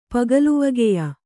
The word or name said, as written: ಪಗಲುವಗೆಯ